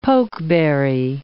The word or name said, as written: pokeberry